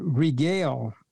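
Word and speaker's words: regel (רגל): And the next word for foot, as far as making a foot or a person having a foot, they use the word regel, spelled R-E-G-E-L. So you have Pam, P-A-A-M, and regel, R-E-G-E-L. And then you had the word ken, spell K-E-N.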